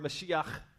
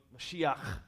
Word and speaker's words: Mashiach: Our English word Messiah is the Hebrew word Mashiach. You got to say it like that with a little bit of clearing your throat. Mashiach.